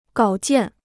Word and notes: 稿件: gǎo jiàn

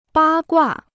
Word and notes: 八卦: bā guà

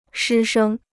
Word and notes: 师生: shī shēng

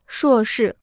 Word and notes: 硕士: shuò shì

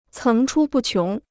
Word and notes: 层出不穷: céng chū bù qióng

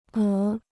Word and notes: 鹅: é

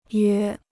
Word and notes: 约: yāo

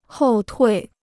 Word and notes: 后退: hòu tuì

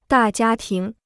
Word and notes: 大家庭: dà jiā tíng

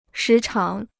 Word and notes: 时常: shí cháng

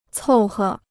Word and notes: 凑合: còu he

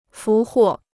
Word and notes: 俘获: fú huò

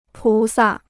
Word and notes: 菩萨: pú sà

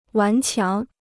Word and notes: 顽强: wán qiáng

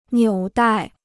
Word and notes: 纽带: niǔ dài

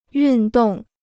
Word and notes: 运动: yùn dòng

